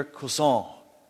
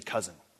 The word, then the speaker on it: Cousin: His name was Victor Cousin. Looks like cousin in English. We would just say cousin. But that's how you say it in French.